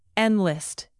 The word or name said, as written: enlist